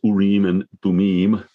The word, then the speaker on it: Urim and Thummim: And as I understand it, you're much better at Hebrew than I am, but I think that's approximately how it ought to be pronounce Urim and Thummim, but the words mean lights in the plural and perfections.